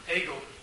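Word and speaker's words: Hagel: Oh, Hegel. – Hegel rhymes with bagel, by the way.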